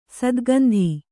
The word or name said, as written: ಸತುಗುಂದು